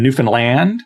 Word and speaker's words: Newfoundland: And by the way, I said that right for those of you who are from Newfoundland. It's the accent is on the land, not on the new, and certainly not Newfoundland.